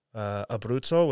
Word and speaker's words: Abruzzo: So my grandparents are from the provinces are Marche and Abruzzo, which are they're kind of neighboring provinces.